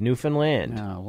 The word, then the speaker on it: Newfoundland: You know, they say, understand, Newfoundland. That's how you say it.